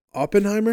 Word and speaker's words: Oppenheimer: Oppenheimer. Am I saying that right? – Yeah.